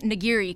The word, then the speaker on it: nigiri: I'm supposed to be saying nigiri, because that's how everyone on the mainland says it.